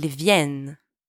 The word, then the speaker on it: viennent: And by the way, don't pronounce the ENT at the end, okay? Silent. Ils viennent.